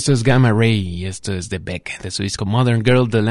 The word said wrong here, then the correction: Modern Guilt: año 2006, Modern Guilt, la pronunciación correcta.